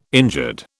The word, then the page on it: injured: British English